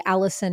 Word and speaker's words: Alyson: Allison is A-L-Y-S-O-N.